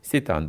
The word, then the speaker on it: Сейтан: Я правильно произношу? – Сейтан. – Сейтан, да, вот это правильно, да.